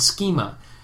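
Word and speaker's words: schema (σχῆμα): Morphe and schema. Did I say that right? Yeah, morphe and schema.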